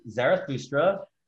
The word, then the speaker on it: Zarathustra: Zarathustra.